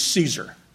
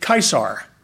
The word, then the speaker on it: Caesar: The man's name was Julius, and he would have pronounced it Caesar, because in Latin there is no soft C sound. All the C's are hard. And the AE sound sounds like a long I.